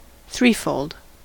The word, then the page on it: threefold: American English